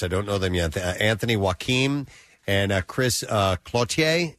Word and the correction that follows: Cloutier: Is that how you say his name? – Cloutier. – Sounds pretty Cloutier. – Good. – Cloutier. – Not really sure. – Yeah, but Clotier sounds very continental.